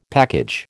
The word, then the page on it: package: American English